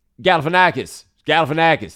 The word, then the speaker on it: Galifianakis: Like, there was a time when Zach Galifianakis, how you say that? – I know what you're saying, and I'm not even going to butcher it. – Yeah, Zach G. Galifianakis.